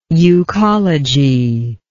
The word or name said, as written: euchology